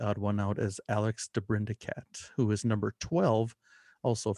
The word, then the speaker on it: DeBrincat: His name is spelled DeBrincat.